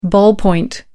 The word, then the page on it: ballpoint pen: American English